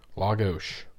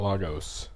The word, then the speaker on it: Lagos: Lagos, not Lagos. We've learned that the hard way.